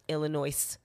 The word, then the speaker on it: Illinois: Okay, for anybody who's listening not from Illinois, not Illinois. – Right, the S is silent. – It's not, one, the S is motherfucking silent.